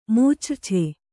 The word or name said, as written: ಮೂಚ್ರ್ಛೆ